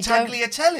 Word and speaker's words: tagliatelle: She reckons you don't pronounce the G in you Tagliatelle.